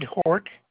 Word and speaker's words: Hort: Ted Hort, H-O-R-T.